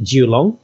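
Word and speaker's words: Julong (九龍): On the other side, there's the area is called Kowloon, and that in Cantonese, you actually pronounce it Julong, which means Nine Dragons.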